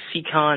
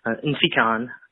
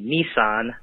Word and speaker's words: Nsikan: It's spelled N-S-I-K-A-N. Last name A-K-P, like in Paul, A-N. My parents pronounce my first name with the N, so it's Nsikon. The first mispronunciation I can remember came in kindergarten. Our school secretary called me Nissan.